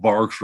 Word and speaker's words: barque: And that thing was, Bark is what it's called. You can spell it like tree bark or B-A-R-Q-U-E.